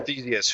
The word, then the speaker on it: Theseus: Joe Theseus, who is the son of Poseidon.